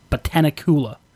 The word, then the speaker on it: Botanicula: I'm going to screw up pronouncing this because it's like Dracula plus Botanical, so it's like Botanicula.